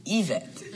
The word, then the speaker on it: Yvette: I believe her name is pronounced Yvette. I was pronouncing it in English.